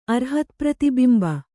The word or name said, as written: ಅರ್ಹತ್ಪ್ರತಿಬಿಂಬ